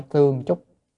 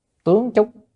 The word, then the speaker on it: tướng: Không phải tương chúc, tướng chúc. Chữ này đọc là tướng mới đúng, đọc tương không đúng.